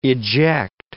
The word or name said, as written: eject